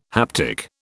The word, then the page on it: haptic: British English